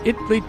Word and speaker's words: Itley: She drew the butterflies that floated quiet like her around Itley, Texas, spelled just like the country, but they pronounce it Itley.